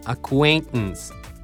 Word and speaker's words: acquaintance: そしてあともう一個ね、教えておくとね、この N と母音に T が挟まれてるでしょ。その後にまた N が来てるとややこしいんだけど、この T ね、 N を飲み込むように発音すると相当発音こなれてるよ。こんな感じ、 acquaintance acquaintance。